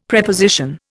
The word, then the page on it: Preposition: Indian